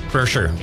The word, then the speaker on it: Kreischer: And then we also have tickets to, I don't know how to say his name, Bert Kreischer? – Kreischer, yeah.